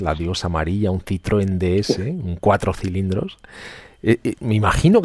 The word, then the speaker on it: DS: DS se pronuncia DS, es como diosa sí.